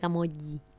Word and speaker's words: Camogli: Camogli se escribe, pero se pronuncia Camogli.